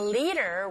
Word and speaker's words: leader: Leader. 자, 디사운드 강몸, 양몸 사이 껴있어요? 네. 드가 아닌 르에 가깝게 들려서 leader.